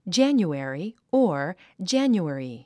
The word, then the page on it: January: dʒǽnjuèri; ジャニュエリィ